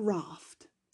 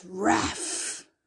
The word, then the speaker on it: wrath: It's raff. Right? I was saying the T at the end instead of where it should be, which is silent because there's a f sound.